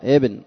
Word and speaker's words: ابن: ابن بدون ألف، كأنك تكتب بن، ونطقها بن خطأ. بعضهم يقول محمد بن علي، هذا بن، هذه ما هي صحيح. بن هذه لغة في البن على لغة أهل صنعاء، هات البن. أما ابن فإنها تقرأ ابن محمد، ابن محمد بن علي، محمد بن علي، محمد بن علي، نعم، بدون ألف.